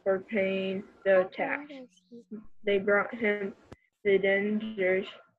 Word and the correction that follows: denarius: I'm pretty sure it's how you say it. – Denarius. Yep. – Denarius.